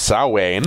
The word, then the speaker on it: Samhain: It's pronounced Samhain.